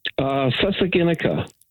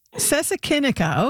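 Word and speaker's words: Sesekinika: Is it Sesakinica? – Sesakinica. – Sesakinica. Okay, good.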